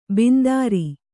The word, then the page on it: ಬಿಂದಾರಿ: bindāri